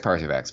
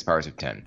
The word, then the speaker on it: Powers of X: That's how you say it, by the way. It looks like Powers of X, but it's Powers of 10.